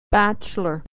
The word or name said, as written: bachelor